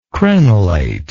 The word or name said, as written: crenelate